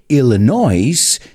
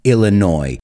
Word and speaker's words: Illinois: Y acordaos, no se dice Illinois, sino Illinois. La S es muda.